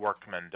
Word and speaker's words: Workman: Workman, W-O-R-K-M-A-N.